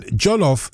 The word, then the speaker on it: jollof: And jollof, the only thing it rhymes with is Wolof.